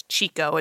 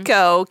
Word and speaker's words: Chicco: I was just casually like thinking about the car seat we have, which we had the Kiko, spelled C-H-I-C-C-O, which I always thought was Chico until you taught me the way.